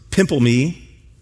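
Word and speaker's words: pimple me (πίμπλημι): And one of those verbs, I wish I would have spelled this for you up here, is pimplemi, pimple, and then mi, if you want to spell it. Pimple my. That's how you'd spell it. That's not how it's pronounced.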